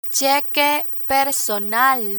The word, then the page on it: cheque personal: spanish